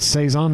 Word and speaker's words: Saison: Like when I think of it, like I'm like Epic makes this like sour apple Saison, and it's so it's Saison. That's how you say it.